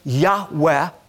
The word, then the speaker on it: Yahweh: And we know that God revealed to Moses that His name was Yud-Heh-Vav-Heh, often pronounced Yahweh.